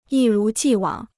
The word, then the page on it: 一如既往: yī rú jì wǎng